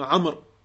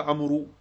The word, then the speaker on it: Amr: Une petite précision, car on l'entend parfois, il y a un waouh ici, mais il ne s'agit pas de le prononcer. On ne dit pas Ibn Amrou, on dit Ibn Amr, pour différencier justement de Omar. Tu ne dis pas Amrou, tu dis Amr.